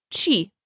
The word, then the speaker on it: che: What is che? In conversation, you'll usually hear it pronounced chi.